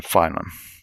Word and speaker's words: phylum: Phylum or phylum? I want to know. It's pronounced phylum.